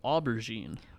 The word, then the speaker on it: aubergine: And an aubergine. – Aubergine, yeah. – Aubergine. Okay, I was close.